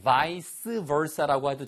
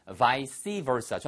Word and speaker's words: vice versa: 발음은 두 가지예요. vice versa라고 해도 되고요. vice versa. 저는 개인적으로 vice versa를 더 많이 씁니다.